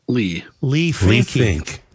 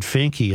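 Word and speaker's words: Leigh Finke: Is it Leah or Lee? – Lee Fink. – Now they're pronouncing it Finky in the legislature.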